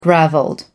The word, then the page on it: gravelled: American English